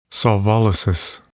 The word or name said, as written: solvolysis